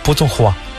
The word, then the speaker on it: Putonghua: Putonghua.